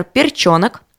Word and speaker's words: Перчонок: А, далее, следующий – это Александр Перчонок. Важно, да, там йо, там ударение на «ё».